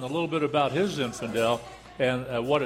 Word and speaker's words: Zinfandel: And let me tell you, it's not Zinfandel, it's Zinfandel. Even though it's an A-L, when you spell it, it doesn't pronounce like an E-L.